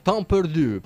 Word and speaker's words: Temps Perdu: Ma più francese, ma scritto però alla cavolo come si pronuncia. – Benissimo, allora ci ascoltiamo questo Temps Perdu, perlomeno così si pronuncia alla francese.